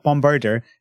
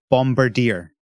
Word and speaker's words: Bombirdier: No, no, you're pronouncing it wrong again. It's not Bombirdor. It's clearly Bombirdier.